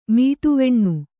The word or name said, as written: ಮೀಟುವೆಣ್ಣು